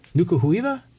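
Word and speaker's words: Nuku Hiva: Nukuhuiva? – Yeah. – Am I saying that right? – Yes.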